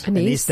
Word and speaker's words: anise: And some of them are really good and some of them They did that one with, is it Anais? Am I saying that right? – Anise.